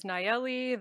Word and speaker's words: Nayeli: Yeah, so my first name is Nayeli. You can pronounce that whatever way works for you.